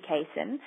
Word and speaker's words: casein: But overall, the dairy protein, I don't know how you pronounce it, casein? – Casein, yeah.